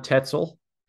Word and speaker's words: Tetzel: I love This next question is from someone named Johan Tetzel. Sounds like pretzel, just without the PR. It's a T instead of a PR. – Johan pretzel. Tetzel, you said.